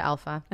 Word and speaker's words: Alfa: Um, they can find me all of my social media and my website is just my name, Aisha Alfa. And how do you spell it so everybody knows? A I S H A. Last name is A L F, like a Friday A.